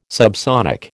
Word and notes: subsonic: American English

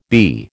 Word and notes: BE: American English

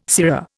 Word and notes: sirrah: Indian

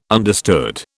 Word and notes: understood: British English

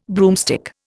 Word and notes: broomstick: Indian

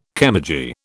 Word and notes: chemurgy: British English

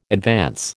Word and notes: advance: American English

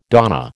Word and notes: donna: American English

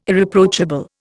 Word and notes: Irreproachable: Indian

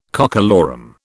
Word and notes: cockalorum: British English

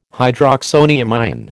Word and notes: hydroxonium ion: American English

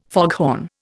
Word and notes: foghorn: Indian

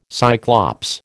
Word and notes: Cyclops: American English

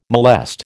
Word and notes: molest: American English

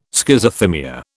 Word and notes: Schizothymia: British English

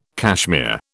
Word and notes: cashmere: British English

